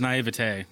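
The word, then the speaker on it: naïveté: I'm mispronouncing it now. – It's naivete.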